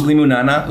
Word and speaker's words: Limonana: Great lemonada. I always say it wrong. – It's limonana. Limonana.